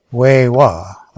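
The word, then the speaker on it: X̱wi7x̱wa: On Wednesday, I attended the event at UBC's Longhouse next to the XI7XIWA Library, or the Native Library, which is pronounced Wei-Wa, I believe, here at UBC on West Mall.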